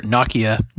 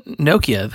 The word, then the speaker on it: Nokia: And Nokia or Nokia, not Nokia, as some people mispronounce it.